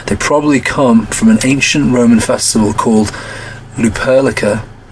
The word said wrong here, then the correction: Lupercalia: or Lupercalia, say it right, probably both wrong, Lupercalia, which took place every February the 15th.